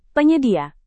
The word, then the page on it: penyedia: Indonesian